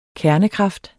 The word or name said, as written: KERNEN